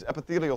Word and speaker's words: epithelial: Parietal cells, G cells, and epithelial. Did I say that right? No. Epithelial.